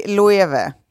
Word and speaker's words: Loewe: Ja, det heter Loewe.